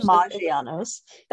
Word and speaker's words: Maggiano's: We went to Maggiano's. Am I close? Mangiano's. I'm I'm very close. – Maggiano's. That sounds but right. If I'm saying it wrong, let me know.